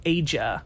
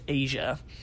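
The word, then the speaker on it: Aja: and that's spelled A-J-A. I used to always pronounce it as Asia, and I felt really stupid when I found out it's just Asia, just spelled in a really beautiful way.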